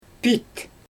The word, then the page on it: piit: Xyzyl